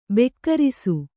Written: ಬಿಗುರಿಸು

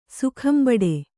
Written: ಸೊಗಂಬಡು

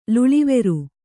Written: ಲುಳಿವೆರು

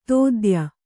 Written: ದೂತ್ಯ

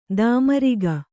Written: ತಾಮ್ರಕ

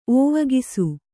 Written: ಓವಗಿಸು

